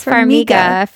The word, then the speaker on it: Farmiga: Farmiga. Isn't that how you say it? Farmiga? – It's Farmiga. Farmiga. Farmiga.